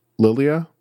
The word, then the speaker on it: Lylla: Groot, and I believe it's pronounced Lilia, L-Y-L-L-A, his now divorced, but his wife for a long time, who is an otter.